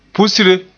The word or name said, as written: pusre